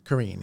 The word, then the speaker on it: Carrine: Carrine, yeah.